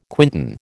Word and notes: quintan: American English

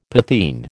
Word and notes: potheen: American English

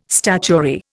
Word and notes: statuary: Indian

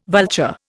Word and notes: vulture: Indian

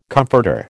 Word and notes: comforter: American English

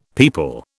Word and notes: people: British English

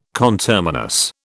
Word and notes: conterminous: British English

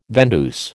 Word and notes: vendeuse: American English

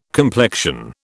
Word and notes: complexion: British English

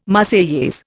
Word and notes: Marseillaise: Indian

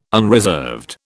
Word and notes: unreserved: British English

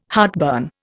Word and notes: heartburn: Indian

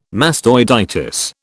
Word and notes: mastoiditis: British English

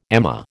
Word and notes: amah: American English

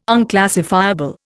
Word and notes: unclassifiable: Indian

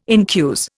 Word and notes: incuse: Indian

